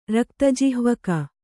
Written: ರಕ್ತಜಿಹ್ವಕ

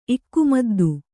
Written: ಇಕ್ಕುಮದ್ದು

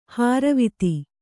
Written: ಹಾರುವಿತ್ತಿ

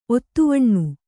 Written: ಒತ್ತುವಣ್ಣು

